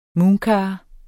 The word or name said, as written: mooncar